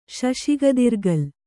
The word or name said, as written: ಶಶಿಗದಿರ್ಗಲ್